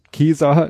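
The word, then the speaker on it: Caesar: Äh, dann kam er auf dieses mit der lateinischen Aussprache C, ob man das wie K oder wie Kesa. Kesa, ne? Also das ist ja wirklich komisch, man würde, keiner würde darauf kommen, Julius Kesa zu ja.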